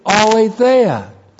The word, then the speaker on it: aletheia: And the word truth, aletheia. A-L, long E, T-H-E-I-A. Aletheia is the Greek word for absolute truth.